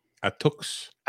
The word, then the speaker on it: Attucks: Crispus Attucks? – Attucks.